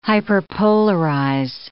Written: hyperpolarize

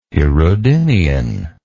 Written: hirudinean

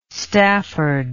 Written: stafford

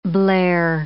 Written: blare

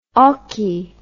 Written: oche

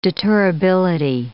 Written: deterrability

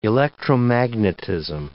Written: electromagnetism

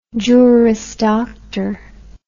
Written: juris doctor

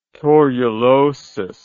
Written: torulosis

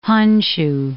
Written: Honshu

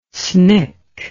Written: SNCC

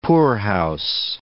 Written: poorhouse

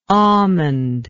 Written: almondy

